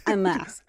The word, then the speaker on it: Hamas: and I do pronounce the KH in mm-hmm. Hamas. – Hamas.